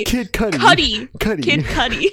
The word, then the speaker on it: Kid Cudi: But I liked the song with Kid Cootie. – Come on, dude. Kid Cudi.